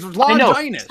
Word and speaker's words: Longinus: She's saying it the right way. It's Longinus.